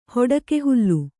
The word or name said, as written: ಹೊಡಕೆಹುಲ್ಲು